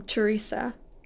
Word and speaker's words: Teresa: I say my name Teresa with three syllables. And then you say Teresa with two syllables. And then somehow Xavier says it with a C-H, like Teresa. Like how the British say like Tuesday.